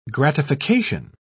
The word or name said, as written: gratification